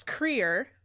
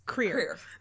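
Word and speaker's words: Krier: Andrew Wilkes Creer, and I know how to pronounce that because of RuPaul. – Yes. – Solid 15 minutes of career. Wilkes Creer.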